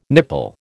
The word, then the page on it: nipple: American English